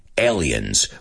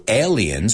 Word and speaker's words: alien: So aliens, vamos a pronunciarlo. Es una palabra que se deletrea igual en ambos idiomas, pero no decimos alien. En inglés está a, ale, like cerveza, ale, alien.